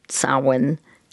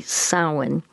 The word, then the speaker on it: Samhain: This harvest festival is pronounced Samhain. The owl reminding you of the word cow, rhyming with that. So it would be Samhain.